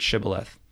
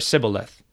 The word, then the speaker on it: Shibboleth: To identify and kill these Ephraimites, the Gileadites told each suspected survivor to say the word Shibboleth. The Ephraimite dialect resulted in a pronunciation that, to Gileadites, sounded like shibboleth, or sibboleth.